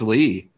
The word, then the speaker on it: Leigh: My middle name is Lee. – Isn't it spelled like L-E-I-G-H? – Correct.